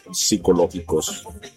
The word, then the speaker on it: psicológicos: Oye, hoy vamos a me estaba, ahorita que dijiste, papi chulo, estaba recordando unos hechos psicológicos, porque la P no se pronuncia, psicológicos.